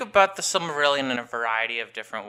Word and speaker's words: Silmarillion: And the Silmarillion, did I say it right? – Yeah.